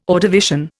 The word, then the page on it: Ordovician: Indian